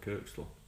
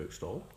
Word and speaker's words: Kirkstall: It's nice that you pronounced it correctly as well. You called it Kirkstall, because a lot of people from outside of Yorkshire look at that and go Kirkstall.